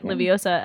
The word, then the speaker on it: Liviosah: You can follow her on Twitter and Instagram. – Yeah, stalk me. Okay. You can stalk Olivia at Liviosa, L-I-V-I-O-S-A-H.